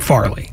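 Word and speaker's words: Fairleigh: How do you say it? – I believe they call it Farley. – Farley. Spelled fairly.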